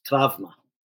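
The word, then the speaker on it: travma (τραύμα): The word trauma itself is an ancient Greek word, even spelled the way we spell it, but pronounced travma in Greek.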